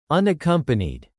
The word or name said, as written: unaccompanied